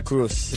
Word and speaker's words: Cruise: Se escribe Cruise. – En inglés. – Se pronuncia Cruz.